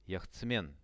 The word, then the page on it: jahtsmen: Russian